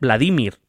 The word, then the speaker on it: Vladimir: Vladimir bueno, por cierto, siempre decimos Vladimir o Vladimir y ninguna de las dos es correcta, es Vladimir, por cierto. Vladimir. Sí, sí, es Vladimir, se tilda en la, sílaba del medio,